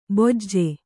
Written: ಬೊಜ್ಜೆ

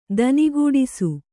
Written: ದನಿಗೂಡಿಸು

